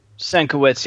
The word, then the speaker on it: Sienkiewicz: And Bill, I can't pronounce his last name, Sienkiewicz, who I guess was maybe the writer or co-author. – Senkiewicz, yeah. Let's say it's pronounced that way.